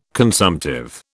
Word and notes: consumptive: British English

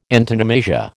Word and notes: antonomasia: American English

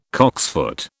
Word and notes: cocksfoot: British English

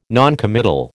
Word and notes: noncommittal: American English

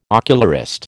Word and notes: ocularist: American English

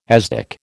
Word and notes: asdic: American English

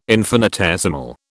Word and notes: infinitesimal: British English

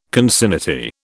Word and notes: concinnity: British English

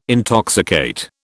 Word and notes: intoxicate: British English

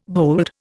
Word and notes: bold: Indian

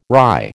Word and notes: rye: American English

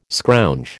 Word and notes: scrounge: American English

